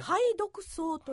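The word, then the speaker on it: 蠅毒草: 排毒草という、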